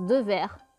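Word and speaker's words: Dewaere: Donc ce qui se prononce de verre et non de S'en S'ensuit ensuite euh, la connaissance du Café de la Gare avec Coluche, Miu Miu, Patrick Devers aimait jouer sur scène.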